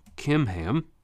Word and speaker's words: Kimham: Verse 17, they head to Geruth Kimham. So in English it's with a CH, but the Hebrew language doesn't have a CH sound like we do, so you pronounce those like a K.